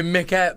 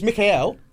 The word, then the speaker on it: Mikael: No, because the Swedish name would be Mikael. – Yeah, so it's Mikael. – Yeah. – But it's spelt Michael. – But with a CH.